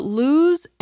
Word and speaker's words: lose: In speaking, both words use the vowel sound oo. But lose ends in the z sound, while loose ends in the s sound.